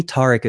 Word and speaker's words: Tariq: So to me, Tariq is the way I say it. Now, I'm pretty sure Tariq, T-A-R-I-Q, is spelled and phonetically sounds closer to it.